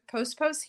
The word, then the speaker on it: Pospos: I have Dr. Pospos here with us today.